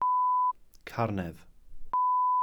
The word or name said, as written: carnedd